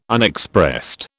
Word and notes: Unexpressed: British English

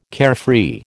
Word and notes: carefree: American English

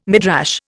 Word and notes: Midrash: Indian

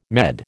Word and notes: Med: American English

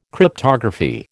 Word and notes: Cryptography: American English

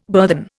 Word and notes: burthen: Indian

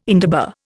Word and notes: indaba: Indian

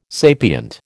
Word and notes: sapient: American English

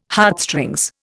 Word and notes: Heartstrings: Indian

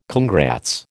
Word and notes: congrats: American English

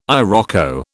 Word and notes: iroko: British English

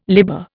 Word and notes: libber: Indian

